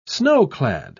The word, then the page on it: snow-clad: English; British English